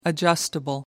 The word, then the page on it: adjustable: American English